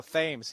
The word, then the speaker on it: Thames: No, Thames. He actually pronounces the TH.